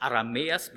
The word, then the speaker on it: Aramäer: Was hier noch hervorgehoben wird, dass Rebekka nach dem Wunsch Abrahams aus der Verwandtschaft Abrahams kommt und dass sie die Tochter des Aramäers Betuel, Betonung liegt auf Aramäer, Aramäer Betuel, die Schwester des Aramäers Laban, aus der Gegend Padan Aram.